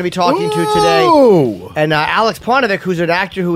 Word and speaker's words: Aleks: A-L-E-K-S. He's Canadian. – All right, Alex. – Yeah, Alex. – All right. It's spelled. – There's no X, yeah. – There's no X in the Alex.